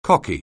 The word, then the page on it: cocky: anglais